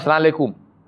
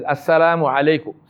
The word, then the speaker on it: assalamu alaikum: It's not salaikum. Right? It's not salaikum. It is assalamu alaikum.